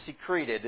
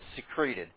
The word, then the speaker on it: secreted: Because without cause, they secreted, or secreted is literally the way that you pronounce it. I know it sounds like something glandular or something chemical, but to make something secret, you do pronounce it secreted.